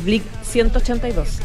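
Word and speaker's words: Blink-182: que es Blick 182.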